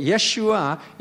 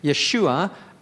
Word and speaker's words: Yeshua: Okay, Yeshua in Hebrew is, there's a silent letter on the end of it.